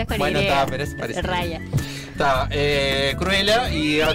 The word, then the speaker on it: Raya: Raya, sí.